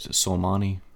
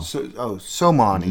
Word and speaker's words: Soleimani: Soleimani? – Yes. – Okay. – Your mama? No. – No. – Soleimani.